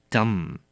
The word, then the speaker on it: dumb: 愚笨的、哑的，就是 dumb。小心了，它的 mb 放在后面，那么放在字尾的部分，我们只发 m，而 b 是不发音的 dumb。